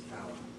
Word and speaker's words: Palau: It's Palau.